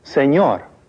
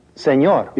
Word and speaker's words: señor: Señor. – The N with the tilde in... – Señor. – Is much like the sound produced when the words can and you are pronounced together. Can you?